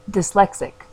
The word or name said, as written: Dyslexic